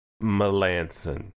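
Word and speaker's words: Melancon: It's Melanson.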